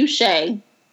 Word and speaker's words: Ushay: The letter U and then Shea. – Yo, because this is Shea Butter? Like, what we doing? – No, it's not spelled like Shea Butter. It's S-H-A-Y. – Mm-mm. – U-S-H-A-Y.